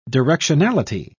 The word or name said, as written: directionality